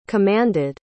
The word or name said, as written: commanded